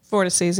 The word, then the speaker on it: vortices: Vortresses. – Vortices, yeah.